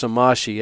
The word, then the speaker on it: Somaschi: I think it's Somaschi, S-O-M-A-S-C-H-I, but he would found this order to work with the orphans.